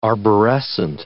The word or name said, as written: arborescent